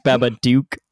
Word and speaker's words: Babadook: I believe it's pronounced Babadook. – Duke, like in a swashbuckler.